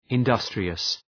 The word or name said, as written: industrious